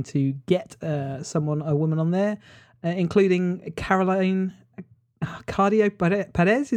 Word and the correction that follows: Criado Perez: Is that how you say it? Criado Perez, anyway, her name.